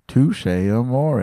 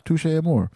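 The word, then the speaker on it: Touché Amoré: Who is this pretentious prick saying Touche Amore? It's Touche Amore.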